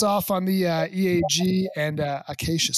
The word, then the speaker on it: Acacia: Brooke is with, make sure I'm saying this right, Acacia. – Like Acacia forgot. We're still here doing our 11,000 barrels a day. – That's right. Like the acacia tree.